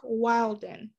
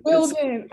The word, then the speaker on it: Wilden: Joining me today in this episode is Eric Wilden. I hope I pronounced your last name right. – It's Wilden, actually. – Oh, but Wilden.